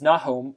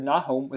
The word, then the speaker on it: Nahom: If in fact the Book of Mormon's Nahum was originally written, or at least pronounced, with a chet, the question then arises as to why Joseph Smith rendered Nahum with a soft H and not a guttural H in his translation.